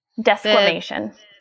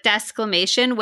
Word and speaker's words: desquamation: Desquamation. – Desquamation.